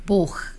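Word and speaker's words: 보흐 (Бог): 자, 러시아어로 하나님을 뜻하는 단어가 뭘지 자 보기 1번이 보그였는데 한번 정확한 발음. – 보흐.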